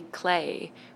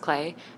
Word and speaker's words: Clea: And another one, a current one, would be Clay, which is spelled C-L-E-A. It's spelled like clear, but it's pronounced Clay.